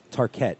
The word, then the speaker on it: Tarkett: Eddie, it's Tarkett. – I was just about to say. – I've been saying it wrong this whole time, huh? – I was just about to say. – We are based in Paris, so that accent, I guess, is on the second half.